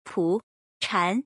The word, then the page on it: 蒲禅: Pu CHan